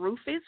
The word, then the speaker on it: Rufus: Superfly, all one word, and Rufus, which is my last name, R U F U S.